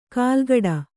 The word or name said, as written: ಕಾಲ್ಗಡ